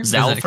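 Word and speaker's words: Zhalfir: Is that how you say it? Zafar? – Zalfir? – Zalfir. – There's an L in there.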